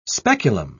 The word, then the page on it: speculum: British English